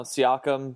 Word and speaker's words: Siakam: Is that how you say his name? Psycam? – Uh, I don't know. Siakam, Psycam. oh, that's right. Siakam. That's how you say it. Sorry, everyone. Totally said it wrong.